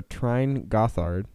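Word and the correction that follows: Tyrn Gorthad: not Gothard. – Yeah, Tirn.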